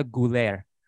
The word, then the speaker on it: Güler: And I said, hey, can you please give me a tutorial how to say this guy's name properly so I don't embarrass myself? Arda Güler is Güler.